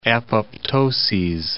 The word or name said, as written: apoptoses